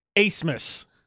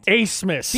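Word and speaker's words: Abmas: So his last name is spelled A-B-M-A-S, I believe. Do you know how it's pronounced? Asmus. – No, it's not. – Asmus. – B is silent? – Asmus is his last name. – Never have I ever existed in a world where the B is silent.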